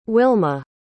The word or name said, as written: Wilma